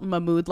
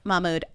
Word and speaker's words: Mahmood: This guy's name is Mahmood, but it's spelt Mahmood like my mood. And it's on purpose. – Mahmood.